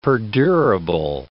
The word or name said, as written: perdurable